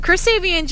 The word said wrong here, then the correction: Chris Sabin: His name's Chris Sabin. – Chris Sabin, I'm so sorry, you threw me off with the Sabin thing.